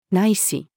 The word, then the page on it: 乃至: female